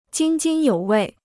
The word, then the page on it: 津津有味: jīn jīn yǒu wèi